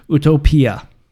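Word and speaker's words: utopía: And technically, it needs an accent over the I, but there's many different words that end in an IA in Spanish, and utopia in Spanish is utopia. Utopia.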